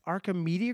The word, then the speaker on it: Archimedes: And they have a company called Archimedes Group. Did I say that right, guys? – You nailed it.